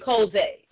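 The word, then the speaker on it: Chozeh: And so the three names for prophet that we're going to see is Nabe, Roe, Jose, and Koze. Amen. And so Nabe is spelled N-A-B-I. Amen. Roe is R-O-E-H. Jose is H-O-Z-E-H.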